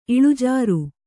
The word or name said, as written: ಇಳಿಜಾರು